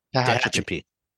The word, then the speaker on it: Tehachapi: How do you say the name of this town? – Tatchby. – Tehachapi? – Okay.